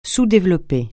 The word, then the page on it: sous-développé: francais